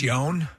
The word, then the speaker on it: Ioan: I think it's Yone. – Yone. I-O-A-N. Yeah. Yone.